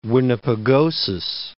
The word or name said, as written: Winnipegosis, Lake